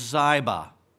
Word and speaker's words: Ziba: Now there was a servant of the house of Saul whose name was Ziba, and that is the correct pronunciation.